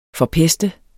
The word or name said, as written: forpeste